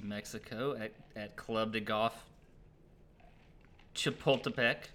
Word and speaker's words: Chapultepec: Why don't you tell us about the course that's hosting for only the third year in a row, Club de Golf Chapultepec. I went ahead and pronounced it for you so you didn't have to butcher it.